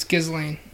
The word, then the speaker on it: Ghislaine: So it's Ghislaine. – Okay, Ghislaine. I was right the first time.